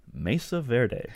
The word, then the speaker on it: Mesa Verde: Mesa Verde.